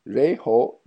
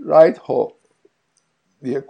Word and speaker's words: Raidho: E, bunu biraz zor telaffuz ediyoruz çünkü yıllar içinde çok değişmiş. Reitho'dan Reitho, Reitho, Reyho gibi birçok e, şekilde de telaffuz edilmiş. Ama biz e, bize program için Reitho diye kullanacağız ki evet.